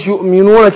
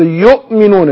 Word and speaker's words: يؤمنون: والذين يؤمنون بما ناخد بالنا من الهمزة، أقول إيه والذين يؤمنون كده، لأن هي الهمزة وإن كانت هي مرسومة واو عليها همزة، لكن أنا هي اللي بتتنطق الهمزة فبحقق الهمزة. يؤمنون يؤمنون بدون مبالغة طبعا.